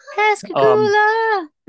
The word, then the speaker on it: Pascagoula: Pascagoula.